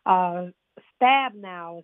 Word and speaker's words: Stabenow: And it is still a bill that is in progress and is backed by Senate Agricultural Committee Chairwoman Senator Debbie Stabenow is how you pronounce it, I believe.